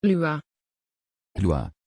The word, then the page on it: lua: Dutch